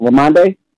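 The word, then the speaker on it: Rhamondre: Is that how you say it? Ramondre? – Yes.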